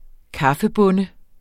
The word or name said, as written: kaffebonde